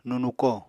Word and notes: nunuko: Apatani

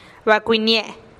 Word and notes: bakuinihnie-e: TdVZ

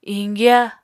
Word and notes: iñgya: apatani

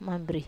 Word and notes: mambiri: Wamesa

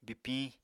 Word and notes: bipiñ: Apatani

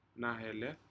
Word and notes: naheled: Aneityum

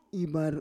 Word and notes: ivar: Roon

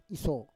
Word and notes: iso: Roon

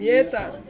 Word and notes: mietang: Ansus

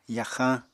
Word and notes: yaxañ: apatani

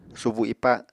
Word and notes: subu ipa: Apatani

